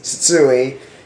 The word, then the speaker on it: Tsutsui: So my last name is Tsutsui, spelled T-S-U-T-S-U-I. If you want to try to pronounce it, think of tsunami, but then say that first syllable twice, and then eat.